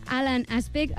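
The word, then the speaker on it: Alain Aspect: Són el francès, a veure si ho diem bé, Alan Aspect, el nord-americà John Clauser i l'austríac Anton Zellinger. – Molt bé aquesta pronunciació.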